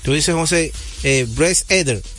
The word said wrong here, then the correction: Elder: Bryce Elder. Elder. Sí, Elder. Eh, los mejores pitchers en ganar Saiyón.